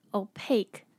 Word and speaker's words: opaque: 再来有一个单字，今天这个单字很特别，要注意它的念法， opaque，它的后面的 que 是念可 k 的音， opaque。